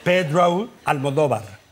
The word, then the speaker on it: Almodóvar: So, anyway, if you want to pronounce it like if you were Spanish. Excuse me that I'm so long. Then it is Pedro Almodóvar.